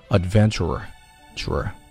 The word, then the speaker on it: adventurer: OK，advent，adventure，adventurer，adventurer，后面还有一个 r，所以说这个 r 字母你听到还发了两次哈、啊，并且发音的时候稍稍有一点滞后的感觉。 adventurer，OK，、 okay, 好，后面。